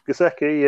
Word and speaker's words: Gesicki: You like Gesicki? Is that how you pronounce his name? – Gesicki, yeah. I don't think I got him this time.